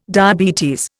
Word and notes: diabetes: Indian

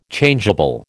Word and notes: Changeable: American English

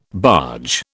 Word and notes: barge: British English

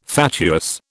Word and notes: fatuous: British English

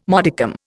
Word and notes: modicum: Indian

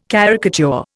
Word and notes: caricature: Indian

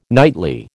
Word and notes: nightly: American English

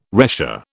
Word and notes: retia: British English

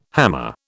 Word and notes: hammer: British English

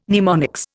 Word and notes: mnemonics: Indian

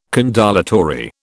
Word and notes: condolatory: British English

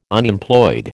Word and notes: Unemployed: American English

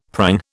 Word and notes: prang: British English

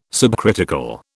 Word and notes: subcritical: British English

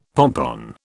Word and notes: pompon: British English

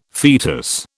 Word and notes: fetus: British English

